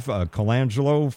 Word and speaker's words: Colangelo: Steve Colangelo from San Joaquin County, running for Board of Supervisors, District 4.